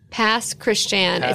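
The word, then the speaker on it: Pass Christian: Can we do a homophilia live show in Pasquashan? – Yes. – Pasquashan? – Pasquashan. It's spelled like Christian, and then it's the French pronunciation.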